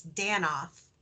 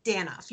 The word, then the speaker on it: Danoff: Could you say your last name for me? – Sure. It's Danoff. – Danoff. Okay. Because the reason I was about to say Tracy Danoff, and I wanted to make sure I did not get the accent or pacing of your last name wrong. So it's Danoff. – Danoff, yes.